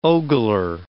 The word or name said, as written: ogler